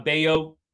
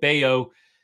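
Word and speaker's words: Bello: But Brian Bayo, sorry, I know I said the wrong pronunciation, can't keep track. But Bayo, he is going to be coming into this game not having pitched his greatest.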